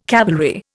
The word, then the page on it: cavalry: Indian